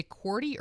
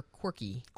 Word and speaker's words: QWERTY: Okay, did you say QWERTY or quirky? – QWERTY, Q-U-W-E-R-T-Y, or Q-W-E-R-T-Y, the first six letters of the keyboard.